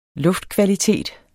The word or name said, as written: luftkvalitet